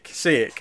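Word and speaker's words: Šejić: Sejek. It's Sejek. Okay. – Sejek. – Yeah. Sejek. It's like saying say it, just with the C sound at the end.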